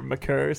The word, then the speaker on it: Maker: McCorr, yeah. – Really? – Yeah. – I had no idea. – Or McCurr, something like that.